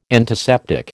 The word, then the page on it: antiseptic: American English